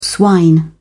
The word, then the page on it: swine: British English